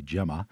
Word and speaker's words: Gemma: New, whose first name is pronounced with a soft G, Gemma,